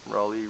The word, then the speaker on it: Raleigh: Except they spell it C-O-L-O-M-B-I-A. Raleigh, North Carolina. Raleigh rhymes with trolley.